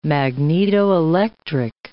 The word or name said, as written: magnetoelectric